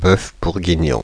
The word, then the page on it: bœuf bourguignon: France (Paris); /bœf buʁ.gi.njɔ̃/